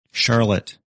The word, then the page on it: Charlotte: English